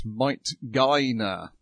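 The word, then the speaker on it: Might Gaine: Then we've got Might Gainer. I think that's how it's supposed to be pronounced. That's how they you got it.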